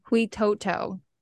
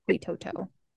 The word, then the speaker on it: Huitoto: Huitoto. That's how you say it. Huitoto.